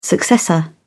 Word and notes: successor: British English

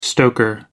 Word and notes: stoker: American English

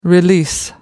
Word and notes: release: American English

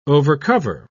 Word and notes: overcover: British English

